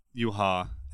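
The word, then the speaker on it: Yhwach: Yuha is introduced as like the main bad guy, and it's spelled Y-W-A-C-H, I think. But it's pronounced Yuha.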